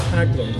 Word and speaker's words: Haglund: I'm just referring folks to the little microphone that is on a tripod stand that separates me from Kyra Haglund.